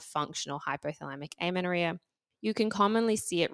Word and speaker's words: hypothalamic amenorrhea: And so one of those is hypothalamic amenorrhea, which I hope I'm pronouncing correctly. – You are.